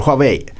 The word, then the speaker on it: Huawei: På det temat så kom också Huawei, hur det nu ska uttalas. Vi säger Huawei. Eh, med eh, lite siffror i alla fall för Q1.